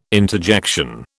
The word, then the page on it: interjection: British English